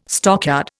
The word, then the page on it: stockyard: Indian